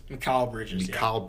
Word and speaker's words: Mikal: Mikal Bridges. I think that's how you pronounce it.